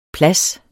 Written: plads

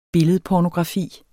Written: billedpornografi

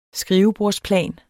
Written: skrivebordsplan

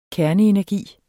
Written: KERNEENERGI

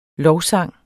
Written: LOVSANGE

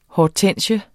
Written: Hydrangea

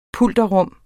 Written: pulterrum

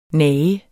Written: nage

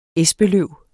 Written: espeløv

